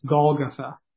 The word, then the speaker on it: Golgotha: In Aramaic, it's pronounced Golgotha.